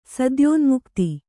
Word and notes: ಸದ್ಯೋನ್ಮುಕ್ತೆ: sadyōnmukte

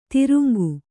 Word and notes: ದ್ರಂಗ: draŋga

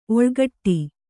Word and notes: ಒಳಗೋಟೆ: oḷagōṇṭe

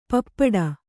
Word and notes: ಪಾಪಟಿ: pāpaṭi

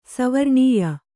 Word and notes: ಸವರ್ಣೀಯ: savarṇīya; kannada

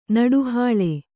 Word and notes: ನಡುಹಾಳೆ: Kannada

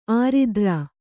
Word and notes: ಆರಿದ್ರ: āridra

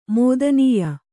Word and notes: ಮೋದನೀಯ: mōdanīya

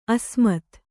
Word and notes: ಅಸಮ್ಮತಿ: Kannada

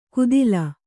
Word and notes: ಕುದಿಲ: kudila

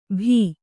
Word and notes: ಭೀ: Kannada; bhī

